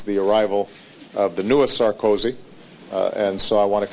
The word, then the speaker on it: Sarkozy: That's how you got to pronounce it. Sarkozy.